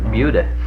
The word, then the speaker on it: Buda: I was in Buda the other day, and they pronounced it Buda. – Buda. – It was a shock to me. I thought it wouldn't be Buddha.